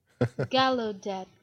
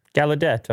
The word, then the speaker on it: Gallaudet: Here we go. Pronunciation. – Long video. – Sounds good. – Gallaudet. – Gallaudet, okay. I was going more French with it, you know? – Okay.